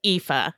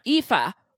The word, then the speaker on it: Aoife: Yeah, it's pronounced Aoife. – Aoife. For setting us straight.